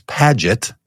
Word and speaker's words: Paget: So my last name is Paget, so it has a ja sound to it, the soft G sound.